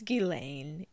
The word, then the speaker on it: Ghislaine: Is it I was about to say her name was Giselle. Her name's not Giselle. It's Ghislaine.